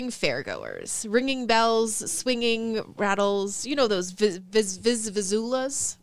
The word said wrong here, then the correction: vuvuzelas: I can never pronounce them properly. – Vuvuzelas? – That's the one.